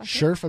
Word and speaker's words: Scherff: Scherff I, Scherff, I believe is how you pronounce it. Sorry if I'm pronouncing that wrong, but I believe it's Scherff.